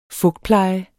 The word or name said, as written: fugtpleje